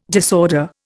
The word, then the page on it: disorder: Indian